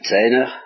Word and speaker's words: Zaehner: Il s'appelle Zener, ça s'écrit Z-A-E-H-N-E-R, et il c'est un spécialiste de religion comparée.